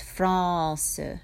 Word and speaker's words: France: So you don't really actually pronounce the N, it just kind of comes through your nose a little bit, and the second syllable is not as accented as the first, France.